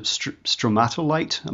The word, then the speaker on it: stromatolite: And one of the most intriguing things for me was, I hope I'm pronouncing this correctly, but it was a very early development in the Earth. Was it stromatolite? Am I saying that correctly? – Yes.